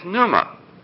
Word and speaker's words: pneuma: Now the Greek word that is translated wind is pneuma, like pneumatic tires.